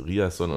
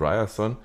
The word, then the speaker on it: Ryerson: Am Ende, wir hatten einen Spieler, vielleicht erinnert ihr euch noch dunkel, der hieß Riasson und Ryerson und hat gesagt, beide Aussprachen sind richtig.